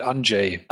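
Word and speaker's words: Andrzej: Also tatsächlich, der Vorname lautet äh, geschrieben André mit einem Z, äh, ausgesprochen wird es wohl Anjay. Anjay, ja. Und äh, der Nachname lautet Sapkowski.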